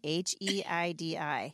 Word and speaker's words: Heidi: HEIDI.